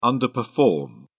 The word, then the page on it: underperform: British English